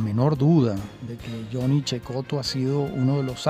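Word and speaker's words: Cecotto: Bueno, naturalmente, como lo denota claramente su apellido, Checoto, que se escribe Secoto con doble T.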